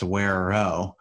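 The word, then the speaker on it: güero: One thing that I did want to say was that Wero, it's pronounced, you know, I've been trying to say it right. It's pronounced Wero in English.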